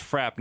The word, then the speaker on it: frap: I believe it's pronounced frap, not frappe. But okay. Like frappuccino.